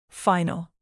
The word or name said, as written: final